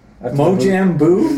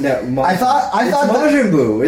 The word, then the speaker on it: Majin Buu: Mojang Buu? – Majin Buu, isn't it? I said it right.